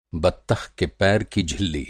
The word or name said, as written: झिल्ली